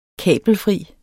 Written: kabelfri

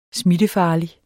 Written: smittefarlig

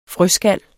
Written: frøskal

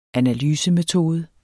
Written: analysemetode

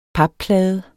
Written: papplade

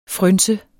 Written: frynse

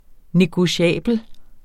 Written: negotiabel

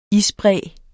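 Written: isbræ

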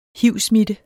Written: hiv-smitte

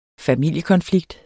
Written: familiekonflikt

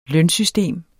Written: lønsystem

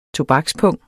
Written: tobakspung

